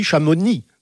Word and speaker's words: Chamonix: Et il faut dire aussi Chamonix. – Oui. – On ne prononce pas le Par X. Contre, on dit Astérix.